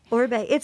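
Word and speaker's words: Oribe: I think you say it Orbe. – Uh-huh. – Is that how you pronounce it, Orbe? – Yeah, Orbe. He's passed, a very famous hairstylist.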